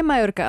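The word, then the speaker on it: Mallorca: nebo z Majorky, ono se to čte asi Majorka. Já právě jako mám pocit, no správně je Majorka,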